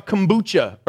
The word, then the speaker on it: kombucha: How many enjoy a kombucha? Or what, am I saying it right? Yeah. If you're, yeah, there you go.